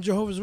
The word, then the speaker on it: Jehovah: I'm a Jehovah's Witness. – Since when? – Oh, Jesus. – You quit the Javajos? – Javajo? No. Wrong. You pronounce it wrong. It's Jehovah. Jehovah's Witness.